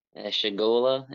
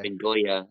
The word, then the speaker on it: Chagolla: Chagola in English. – Chagola? Okay. – Chagoya in Spanish.